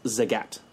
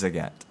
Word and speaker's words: Zagat: Because actually, I believe it's pronounced Zagat. – It's Zagat.